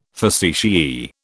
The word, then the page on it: facetiae: British English